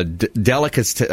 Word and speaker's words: delicatessen: It is a restaurant, a uh, delicatessen. delicacy Cassin. Uh, How do you pronounce that again? – Delicatessen.